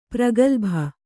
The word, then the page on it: ಪ್ರಗಲ್ಭೆ: kannada